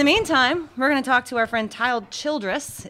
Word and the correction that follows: Tyler Childers: Is that, did I say it right? – Tyler Childers. You can Childers.